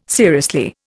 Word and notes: seriously: Indian

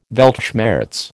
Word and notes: Weltschmerz: American English

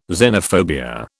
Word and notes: xenophobia: British English

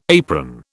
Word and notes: apron: British English

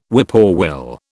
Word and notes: whippoorwill: British English

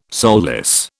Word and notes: soulless: British English

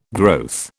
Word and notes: growth: British English

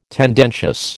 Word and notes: tendentious: American English

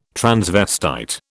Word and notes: transvestite: British English

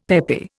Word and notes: peppy: Indian

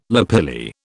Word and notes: lapilli: British English

